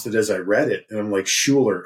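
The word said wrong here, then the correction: Schuyler: And I felt like I was in Ferris Bueller's Day Off, because I just went, Shuler, Shuler, Shuler. And then finally, the young man speaks up and goes, it's pronounced Skyler.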